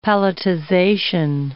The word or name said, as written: palletization